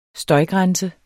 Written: støjgrænse

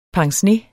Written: pincenez

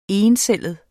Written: encellet

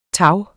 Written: tag

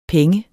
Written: penge

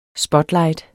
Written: spotlight

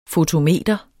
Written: fotometer

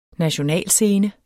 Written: nationalscene

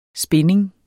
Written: spinding

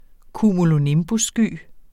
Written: cumulonimbussky

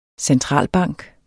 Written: centralbank

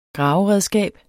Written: graveredskab

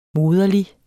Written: moderlig

